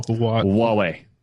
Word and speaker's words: Huawei: Well, you've got IBM, Intel, Samsung, Microsoft, and that one company I can never pronounce. Can someone help me out? – Huawei. Yeah, Huawei. How is that not obvious? It's Huawei. Huawei.